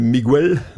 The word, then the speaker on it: Miguel: We hebben hier Miguel, op zijn Spaans uitgesproken.